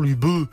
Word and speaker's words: Luberon: Ils ont donc imposé la prononciation Luberon, et non pas Lubé.